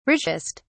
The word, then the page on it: richest: British English